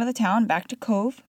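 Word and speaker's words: Cobh: That's how you pronounce it. C-O-V as in Victor, E as in Edward. However, that's not how you spell it. It is actually spelled C-O-B as in boy, H, Cove.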